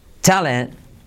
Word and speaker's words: talent: La T, de talent, mírate que casi no se dice.